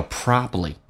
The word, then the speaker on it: probably: 你或许 probably，那么通常第二个 B 是不发音，叫 probably。